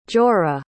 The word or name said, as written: jorah